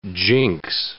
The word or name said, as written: jinx